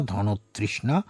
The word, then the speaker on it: ধনতৃষ্ণা: ধন যোগ তৃষ্ণা, ধনতৃষ্ণা, এর অর্থ হল লাভের প্রবল আকাঙ্ক্ষা। আপনারা লক্ষ্য করেছেন যে ধনের সঙ্গে পরে যে শব্দটি যুক্ত হয়েছে, আমরা যখন যুক্তভাবে উচ্চারণ করেছি তখন আমরা ধনের নয়ের উচ্চারণটি ওকারান্ত করেছি।